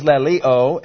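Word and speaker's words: laleo (λαλέω): One is laleo, L-A-L-E long O.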